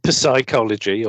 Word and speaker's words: psychology: Mean, I'm no expert on psychology or whatever they call it. Yes, that, that's that's one of those words with a silent p, isn't it? – Yeah.